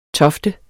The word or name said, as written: tofte